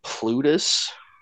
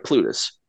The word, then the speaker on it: Plutus: I had to uh, kind of struggle with it, but I believe the uh, second uh, boat person is, uh, I believe it's uh, pronounced like Plutus and uh, he is the yeah, or sorry, yeah, uh, yeah, Plutus.